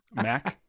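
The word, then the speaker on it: Mack: Yeah, that one's pretty straightforward. – Or maybe remove the C, M-A-K. – I mean, people do spell it wrong all the time. They just spell it as M-A-C. They don't put the K on. – No, sure. – But nobody's ever pronounced it wrong.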